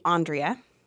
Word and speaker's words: Andrea: So my full name's actually Andrea.